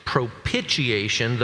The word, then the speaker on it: propitiation: Okay, so it's like, here's, I don't know of any other word that I would say that I can point to pronounce this propitiation, though that's the way I was taught to say it. But I do think of the word initiation, right?